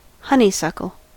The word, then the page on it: honeysuckle: American English